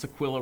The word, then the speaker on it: Aquila: I know some people say Aquila, but since Aquila rhymes with Priscilla, I keep it Aquila and Priscilla.